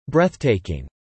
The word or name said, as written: breathtaking